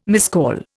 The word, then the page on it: miscall: Indian